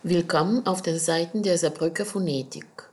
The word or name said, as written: willkommen